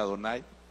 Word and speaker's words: Adonai: escribe Yahweh, pero se pronuncia Adonai.